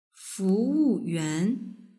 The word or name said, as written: fuwuyuan